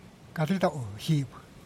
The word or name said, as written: caritau jib